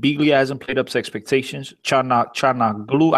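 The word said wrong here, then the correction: Çalhanoğlu: I can't pronounce this guy's name. – Calhanoglu.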